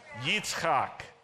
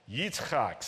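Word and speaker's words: Yitzchak (יצחק): In Hebrew, how do you say Isaac? Yitzchak. Yitzchak, say that with me, Yitzchak.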